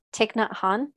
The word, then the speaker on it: Thich Nhat Hanh: these little excerpts from Thich Nhat Hanh.